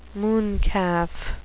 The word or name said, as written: mooncalf